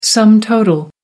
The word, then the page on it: sum total: American English